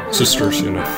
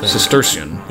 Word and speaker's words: Cistercian: When he was 16, he ran away from his life in the slums and went to Rhode Island to join the Cistercian monks. Is it Cistercian or Cistercian? – Cistercian. – Cistercian. Yeah. Okay, I'll just say it like that.